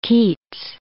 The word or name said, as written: Keats